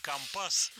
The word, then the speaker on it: компас: Обрати внимание на ударение. Профессиональное. Компас, компас, нефти.